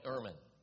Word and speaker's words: ermine: I even had to find out how to say it. It's called an ermine. E-R-M-I-N-E. an ermine.